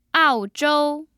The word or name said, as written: aozhou